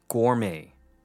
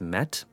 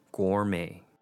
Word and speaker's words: gourmet: And our last one would be gourmet, two syllables. Gore here sounds like the word or, but is supported with the letter G in front. May here actually isn't pronounced met, but May, like the month of May. This word originated from France, so it has more of a French pronunciation, gourmet.